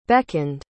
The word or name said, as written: beckoned